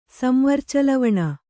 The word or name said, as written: ಸಂವರ್ಚಲವಣ